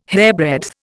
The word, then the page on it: hairbreadth: Indian